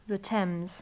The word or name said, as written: the thames